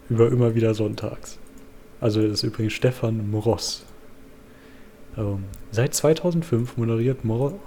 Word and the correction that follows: Mross: Wie kann man den aussprechen? – Also er heißt M. – Ross, aber zusammengeschrieben. Mross. – Mross.